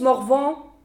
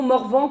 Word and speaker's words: Morvan: On prononce Morvan comme un âne, et non Morvan comme savant.